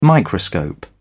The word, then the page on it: microscope: British English